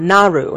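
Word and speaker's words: Naaru: The cool thing is here is the Naru, is how you pronounce it,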